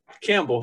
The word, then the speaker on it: Campbell: Uh, Campbell, I like the soup.